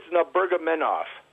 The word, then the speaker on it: Nurmagomedov: I believe it's Nurmagomedov.